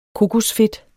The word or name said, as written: kokosfedt